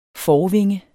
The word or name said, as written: forvinge